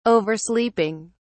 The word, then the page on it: oversleeping: British English